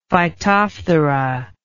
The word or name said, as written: phytophthora